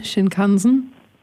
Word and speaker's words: Shinkansen: Der nennt sich Shinkansen. Wie wird das richtig ausgesprochen? – Shinkansen. – Dann heißt er so.